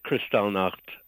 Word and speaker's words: Kristallnacht: You know, calling it Kristallnacht is perfectly acceptable.